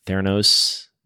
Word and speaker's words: Theranos: Like you've made a couple statements about startups, like Theranos. Did I pronounce that correctly? – Yes.